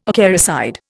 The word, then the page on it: acaricide: Indian